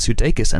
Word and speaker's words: Sudeikis: And the hilarious star of Wheeler Miller's, the former Saturday Night Live stalwart, Mr. Jason Sudeikis, and that is also how you pronounce that.